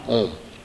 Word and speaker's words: Om: If you put too much stress on A and U and Ma is less stressed, one result.